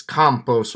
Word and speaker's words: Campos: And if I said Campos, it's Campos with like a long A. So Campos, Sacora.